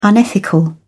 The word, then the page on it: unethical: British English